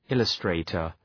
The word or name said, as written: illustrator